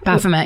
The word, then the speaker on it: Baphomet: He created stained glass window pieces that he used as the windows of a house, including a stained glass Baphomet sigil. – Baphomet, yeah. – Did I say that right? – Mm-hmm.